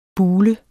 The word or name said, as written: bule